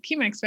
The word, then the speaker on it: Chemex: I have my Chemex. I don't even know if I'm saying that correctly. Chemex, right? – The Chemex, yeah.